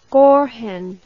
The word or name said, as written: gorhen